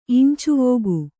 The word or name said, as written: ಇಂಚೆವೋಗು